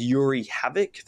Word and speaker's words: Yoeri Havik: I believe his name is Yoeri Havik. That's how you pronounce it.